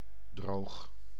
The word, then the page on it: droog: /droːx/